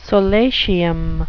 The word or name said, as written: solatium